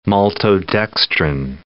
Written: maltodextrin

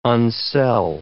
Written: unsell

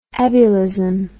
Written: ebullism

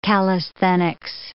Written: calisthenics

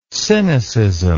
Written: sinicism

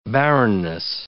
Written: barrenness

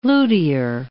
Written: luthier

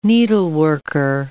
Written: needleworker

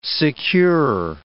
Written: secure

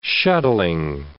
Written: shuttling

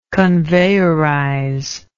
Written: conveyorizer

